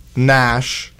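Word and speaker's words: NASG: Up next, we got Lonely Again by Nash. The G is silent.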